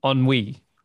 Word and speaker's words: ennui: Ennui.